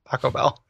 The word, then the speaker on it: Paco Bell: Paco Bell, because it rhymes with Taco Bell, and it sounds like someone whose first name is Paco, which is already a name, and his last name is Bell.